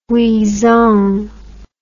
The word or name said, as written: Huizong